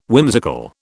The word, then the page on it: whimsical: British English